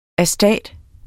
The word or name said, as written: astat